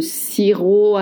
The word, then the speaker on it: sirop: I do miss le sirop à la menthe. Le sirop à la menthe. Don't pronounce the P at the end of sirop.